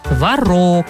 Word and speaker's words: творог: Ударение на втором слоге. И вот вам подсказка-запоминалка. Испеку сейчас пирог, положу туда творог.